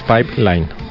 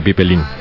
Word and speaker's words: pipeline: Bueno, lo primero es que es pipeline, No se dice pipelín.